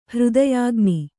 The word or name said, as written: ಹೃದಯಗಾಣ್